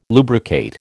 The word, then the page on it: lubricate: American English